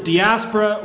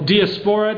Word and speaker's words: diaspora: The Greek word is diaspora or diaspora, depending on which syllable gets the emphasis.